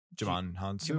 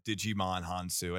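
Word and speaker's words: Djimon Hounsou: It's like spelled Digimon. – Yeah, that's what I was thinking. – That can't be how it's pronounced. – It's like Django. – Yeah. – Because it's like DJ. – Sure. – I heard somebody pronounce him Digimon Hounsou, and I was like, I was like, bullshit, that's how it's pronounced.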